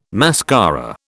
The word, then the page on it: mascara: British English